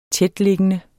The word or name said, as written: tætliggende